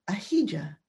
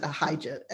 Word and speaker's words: Ahijah: Sometimes I pronounce it Ahijah. Uh, that's the, an anglicized pronunciation. Same guy, anyway. He's a true prophet of God. It's been several years by now, and Ahijah has grown old and blind.